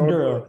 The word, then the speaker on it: Oduro: Yeah, it's Dr. Oduro.